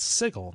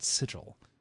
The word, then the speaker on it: Sigil: I didn't know it until I heard it in the game. I was like, oh, it's Sigil, not Sigil. But it's spelled Sigil.